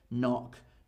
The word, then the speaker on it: knock: And my last one today, when the letter K is before the letter N, the letter K is normally silent. So knit, knock, know, and knowledge. Usually a K before an N is silent.